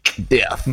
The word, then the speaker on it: Death: T'as vu comme je le prononce bien J'ai pas entendu, vas-y, refais-le. – Death. Death. – Death. Magnifique.